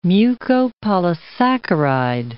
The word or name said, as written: mucopolysaccharide